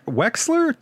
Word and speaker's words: Wexler: Wexler? W-E-X-L-E-R? I'm saying that right.